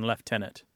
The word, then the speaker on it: lieutenant: So you're in Canada, and you follow, at least for this particular word, the more traditional British pronunciation, lieutenant.